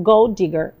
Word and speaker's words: gold digger: Termina com D, começa com D, você lê uma vez só. Então, Gold Digger, Gold, gold digger, digger, tá?